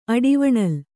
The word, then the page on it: ಅಡಿವಣಲ್: Kannada